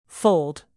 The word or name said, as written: fold